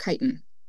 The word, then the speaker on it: chitin: Is it chitin? – Chitin. – Chitin. Okay.